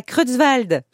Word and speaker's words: Creutzwald: Prochaine date à Creutzwald. J'espère que ça se prononce comme ça. – Oui, ça je suis sûre.